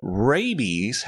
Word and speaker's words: rabies: But rabies has a long A and a long E sound, so the two are quite a bit different in that respect.